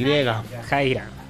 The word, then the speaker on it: Yajaira: Y porque lo voy a pronunciar como uruguayo, pero seguramente usted lo pronuncie mejor que yo. La banda se llama Yajaira, o Yajaira, porque arranca con Y. – Yajaira. – Bueno, para mí es Yajaira, así que...